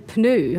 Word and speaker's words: Pneu: Also, in einem Rad hat es einen Schlauch und einen Pneu. Habe ich es jetzt richtig gesagt? – Sehr gut, ja, ganz richtig, aber nicht immer.